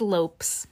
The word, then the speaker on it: Lopes: So twins Gus and Louis Lopez. No, that's not pronounced Lopez. I'm sorry. I was like, girl, her grandson's got the last name Lopez? No, It's Lopes. I pronounced it wrong. The twins are Gus and Louis Lopes, L-O-P-E-S, 13, and Freddie Parker Bowles, 13, as well as her great-nephew, Arthur Elliott, 10.